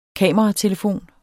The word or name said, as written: kameratelefon